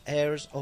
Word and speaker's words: Heirs: Και θα μιλήσουμε και με τον Ιωσήφ Τσομπανόπουλο από τους Heirs of Apollo. – I like the way you said it perfectly. Airs absolutely perfectly. – Apollo, ναι, lovely. It's a silent H.